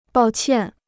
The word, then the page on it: 抱歉: bào qiàn